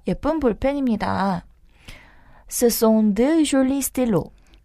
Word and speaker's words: jolis: Les stylos sont jolis. – 그쵸, joli에는 s가 붙지만 발음이 변화가 없기 때문에 앞에 있는 명사로 단수와 복수를 구분하시면 됩니다.